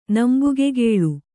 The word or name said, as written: ನಂಬುಗೆಗೇಳು